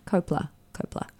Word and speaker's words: Coppola: Coppola.